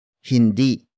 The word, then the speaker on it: hindi: Hindi mababasag ang garapon. Hindi mababasag ang garapon. The first word is hindi. Hindi. Hindi. Hindi is spelled as H-I-N-D-I. It is a negation word, which closely translates to no or not.